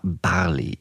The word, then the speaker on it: Barley: Heute spreche ich mit der 53-jährigen Volljuristin Katharina Barley. Ihr Motto lautet laut Eigenauskunft erfrischend politisch. Über ihren Nachnamen sagt sie, er wird für Bob Marley ausgesprochen. Katharina Barley ist Mitglied der SPD.